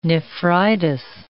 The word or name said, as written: nephritis